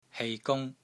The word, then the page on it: HEI3 GUNG1: Cantonese